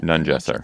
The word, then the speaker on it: Nungesser: I've been saying Nungesser, but Nungesser.